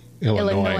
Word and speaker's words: Illinois: Illinois. I'm sorry. I say it wrong. Brother Mod said Mo heard the S in Illinois is not being silent.